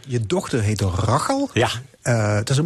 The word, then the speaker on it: Rachel: En Rachel, je moet het eigenlijk uitspreken als Rachel, want dat vond zij heel vervelend.